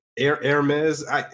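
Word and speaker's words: Hermès: Hermes.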